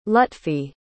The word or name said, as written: lutfi